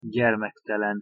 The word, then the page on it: gyermektelen: Hungarian